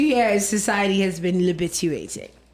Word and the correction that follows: latibulating: I'm saying it wrong. – The word is libituating. – Latibulating. Yeah. Latibulate.